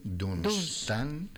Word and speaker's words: Dunstan: Felicidades a los Dunstanes, N-S, Dunstan. Dunstan con acento en la A, Dunstan de Canterbury, hoy San Dunstan.